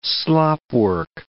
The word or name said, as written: slopwork